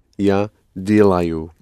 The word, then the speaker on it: делаю: Only accent it on the first syllable. – Я делаю.